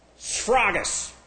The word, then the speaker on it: sphragis: It's S-S-P-H-R-A-G-I-S. This is S-P-H-R-A-G-I-S. Sragus. A sragus. Sragus, Fraga. I don't know if the A is long or short there, but it's sragus or sragus.